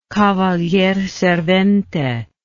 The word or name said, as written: cavalier servente